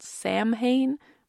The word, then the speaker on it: Samhain: It's spelt Samhain, but apparently it's pronounced Samhain, which is something I only just learned.